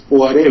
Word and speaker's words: Poiré: Pero me parece que este es apellido y es Poiré, ¿verdad? Con acento en la E.